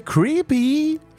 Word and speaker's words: creepy: C'est creepy. Faut le prononcer comme ça.